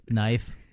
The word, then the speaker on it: knife: M-E-E-T, M-E-A-T. I mean, there's just so many different combinations of words. – All the silent letters for no reason. – Yeah. – Like knife. – Yeah. Makes no sense. – Yeah. – Like there's a K there. – Yeah. – Well, why isn't it? Knight. Why isn't it N-I-F-E?